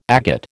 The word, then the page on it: agate: American English